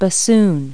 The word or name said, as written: bassoon